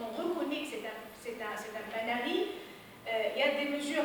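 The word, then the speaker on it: panaris: La palarie, c'est au singulier, ça prend un S, mais le S ne se prononce pas, c'est un S muet. Donc, ça s'écrit avec un S, mais ça se prononce palarie, c'est pas palaris.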